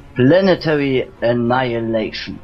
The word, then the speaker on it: Planetary Annihilation: Planetary Annihilation.